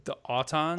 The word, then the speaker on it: Autons: The Autons. – Autons.